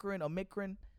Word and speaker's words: Omicron: Omarion. – In Africa. – Omicron. – It started Omicron. – Omicron. Omicron. I'm trying to pronounce that shit.